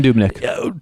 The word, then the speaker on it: Dubnyk: Yeah, Devin Dubnyk.